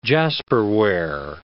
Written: jasperware

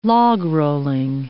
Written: logrolling

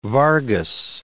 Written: Vargas

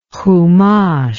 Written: humash

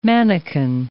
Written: mannikin

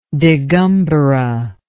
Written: digambara

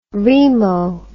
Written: remo

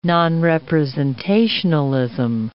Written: nonrepresentationalism

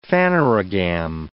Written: phanerogam

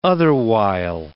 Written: otherwhile

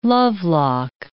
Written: lovelock

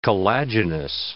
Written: collagenous

